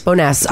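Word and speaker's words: Bowness: It was posted on the, is it pronounced Bonus Neighborhood? in Calgary? – Boness.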